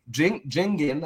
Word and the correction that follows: Jigen: however you say his name, Jigen, he's an Otsuki.